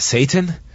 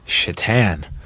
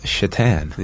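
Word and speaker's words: Šatan: And in a reference probably no one cared about, The airport attendant said, "Your name is Satan?" And Goldberg said, "It's pronounced Shatan." And she says, "Like the hockey player?" – Yeah. – Miroslav Shatan.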